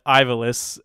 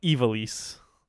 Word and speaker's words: Ivalice: This is another one that has two pronunciations. I call it Ivalice, and some people call it Ivalice.